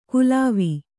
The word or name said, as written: ಕುಲಾವಿ